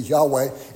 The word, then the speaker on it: Yahweh: And that's the, if you pronounce it as it should be pronounced, I think most scholars agree it should be Yahweh.